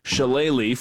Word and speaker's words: shillelagh: I think I could practice spelling shillelagh for the next decade. And I wouldn't get it right. But am I at least pronouncing it correctly? – You're pronouncing it perfectly.